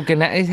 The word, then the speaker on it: gnats: Yeah, the little gnats. How do you... Is gnats. – It? Gnats. – They're just gnats. The G is silent. Gnats.